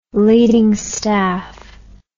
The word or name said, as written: leading staff